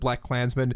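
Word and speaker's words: BlacKkKlansman: I think you would just pronounce it Black Klansman.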